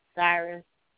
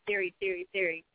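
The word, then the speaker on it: Sirius: Or you can get at us on TuneIn, Last FM, Cyrus. Exit. Oh, snap, I said it right, y'all. You know, yesterday I kept saying Siri.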